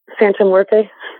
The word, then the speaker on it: Santa Muerte: Santa Muerte? Is that how you pronounce it? – Santa Muerte.